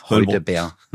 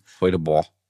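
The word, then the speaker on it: Heudeber: Oder wie man im Harz, glaube ich, sagt, Heudebeer. – Eine Silbe. Heudebeer. Heudebeer.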